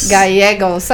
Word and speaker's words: Gallegos: Gallegos.